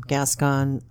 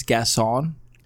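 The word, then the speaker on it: Gascón: Gascon. – Are you sure that? I think that you're saying it wrong. I think it's Gascon.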